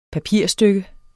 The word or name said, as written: PAPIRSTYKKE